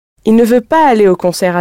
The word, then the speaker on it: il ne: Very often, in spoken French, the il and the ne are pronounced really close together, il, which means you can barely hear the ne.